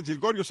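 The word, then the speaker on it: Gilgeous: Y en ese encuentro, el héroe de la jornada, Gilgorius, ¿verdad? Gilgorius se pronuncia.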